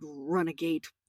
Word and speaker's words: runagate: I just want to stop you at this word because run-a-gate, it's spelled, right? We know the word renegade, yeah? – Yeah. – So that's what it is going to evolve into, this word. But it starts as run-a-gate, which means fugitive, yeah?